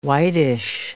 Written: whitish

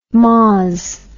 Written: mozz

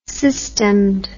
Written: systemed

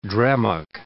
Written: drammock

